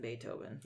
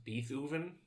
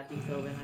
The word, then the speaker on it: Beethoven: Okay, she looks like the little girl that was in Beethoven. I think that's what I'm thinking of. – Pronounced Beethoven? – Sorry, yeah, Beethoven.